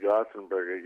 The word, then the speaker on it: Gothenburg: Well, we were talking over there, and in Göteborg, Sweden, or we pronounce it Gothenburg,